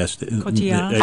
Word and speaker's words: Cotillard: Cotillard.